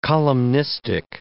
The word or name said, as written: columnistic